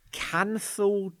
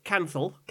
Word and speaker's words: canthal: I'll spell it for you. – Great. – C A N. – Yeah. – T H. – Yeah. – A L. – Canthal. – Canthal. – Yes.